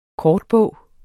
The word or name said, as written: kortbog